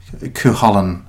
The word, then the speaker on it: Cú Chulainn: It's based on the Cú Chulainn myth. Which is an Irish myth, or it's Cú Chulainn as well, because when you're in the north, you soften the second syllable on anything.